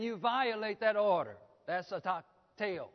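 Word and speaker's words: a takteo (ἀτακτέω): Idleness, or the word idle, as a verb, it's a takteo. Phonetically, it's spelled A-T-A-K-T-E-O, a takteo.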